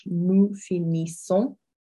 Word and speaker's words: finissons: So nous finissons, so we finish, nous finissons. So your NS, well, your N is an un sound, and then your S on the end there is silent, as is often the case.